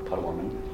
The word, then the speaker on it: Potawatomi: This is the land of the Council of Three Fires, which is the Ojibwe people, Ottawa people, and Potawatomi. I think I'm pronouncing that right. – Potawatomi. – Thank you. You're right. I threw the syllables in there.